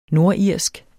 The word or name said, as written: NORDIRSKE